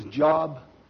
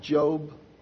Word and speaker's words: Job: In the Bible, it looks like his name is Job. His name is pronounced Job.